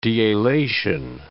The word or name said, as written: dealation